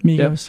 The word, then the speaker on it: Migos: Migos, yep.